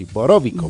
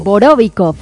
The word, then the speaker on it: Borovikov: Métele acento en Borovikov, así, Borovikov. – Borovikov.